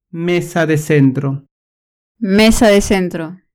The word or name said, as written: mesa de centro